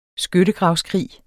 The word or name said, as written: SKYTTEGRAVSKRIG